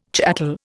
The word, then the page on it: chattel: Indian